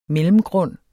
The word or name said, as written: mellemgrund